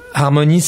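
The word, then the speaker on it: L'Art'Mony's: C'est alors, Harmonie, ça s'écrit pas avec un H, avec un I et tout ça, hein, comme l'harmonie. C'est l'art, A-R-T, apostrophe, Moni, M-O-N-Y, apostrophe, S.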